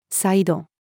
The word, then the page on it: サイド: female